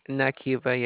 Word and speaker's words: Nakeiva: Is Nakiva. – Nakiva? – Nakiva, yes. – How do you spell it? – N-A-K-E-I-V-A. – Oh, Nakiva. – Yes. – N-I-K-I-V-A.